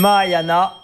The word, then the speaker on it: Mahayana: C'est dur à prononcer, peut-être la A, Mahayana ? – C'est la bonne réponse, ouais. Mahayana.